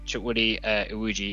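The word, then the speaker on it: Chukwudi Iwuji: It's Chuck Woody Iwuji. – Yes, that's right. Yeah. – Hopefully I've pronounced that correctly. – Yeah. Chuck Woody Iwuji.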